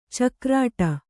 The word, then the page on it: ಚಿಗುರಿಡು: ciguriḍu